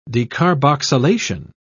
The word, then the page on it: decarboxylation: British English